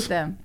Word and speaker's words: Statham: No S, no plural. – Death Race? – Statham.